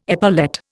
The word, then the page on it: epaulette: Indian